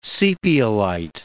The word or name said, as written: sepiolite